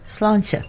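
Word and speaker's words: Sláinte: Slántha. – Slántha. But it's spelled like S-L-A-I-N-T-E, correct? – That's right. – So it's slántha.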